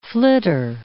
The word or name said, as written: flitter